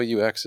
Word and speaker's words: Siouxsie: But in English, Sue, it's easy. And now you know. – Yeah, you're right. It is Sue. Siouxsie is Sue.